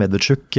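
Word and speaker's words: Medvedchuk (Медведчук): Medvedchuk, ja.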